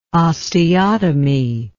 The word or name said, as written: osteotomy